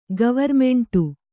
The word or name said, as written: ಗವರ್ಮೆಂಟು